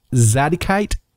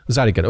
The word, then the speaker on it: Zadokite: Do you pronounce it Zadikite? – Zadokite.